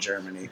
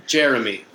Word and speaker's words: Jeremy: Like, and, like, as a Jewish kid in New York, you don't want to be called Germany. – Jeremy. – Right.